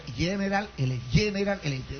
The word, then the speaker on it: General Electric: Entonces resulta que a mí me preocupa el hecho, porque, bueno, dijeron los del gobierno que iban a hacer un convenio con General Electric. ¿Está la pronunciación? ¿Qué le parece? – Muy bien. – Ve que molleja, ve, General Electric.